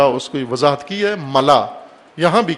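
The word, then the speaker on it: ملاء: ملا، ملاح نہیں ہے، غلط تلفظ کرتے ہیں بعض، ملا، میم لام الف حمزہ، ملا حمزہ کے ساتھ، خلا جس طرح کہتے ہیں، خلا کہتے ہیں غلط تلفظ میں، خلا، ملا، خلا، ملا۔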